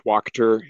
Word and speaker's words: Wachter: It's my last name is pronounced Wachter. It rhymes with Doctor. Wachter. Okay. – Sorry. It rhymes with doctor, if that's of any use.